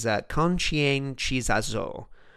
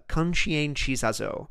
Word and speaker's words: conscientização: And that is conscientização. And that's as good as my pronunciation is going to get. So one more time, it's conscientização.